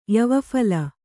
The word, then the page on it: ಯವಫಲ: yavaphala